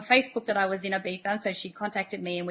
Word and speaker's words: Ibiza: Now, Jinny and I actually met in Ibiza, in Spain. Now, for those of you who don't know Ibiza or Ibiza, depending on how you pronounce it, it's an island off the coast of Spain.